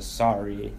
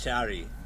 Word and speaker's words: sari: You sounded like you said shari before with an H. I thought it was a sari. – Sari's the American. – That's what the other guests were wearing? – That's American pronunciation.